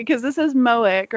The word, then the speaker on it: Moench: I went and checked with Ray from the Moon Knight podcast, Into the Night, and he said, according to the man himself, it is pronounced Mensch, like wrench.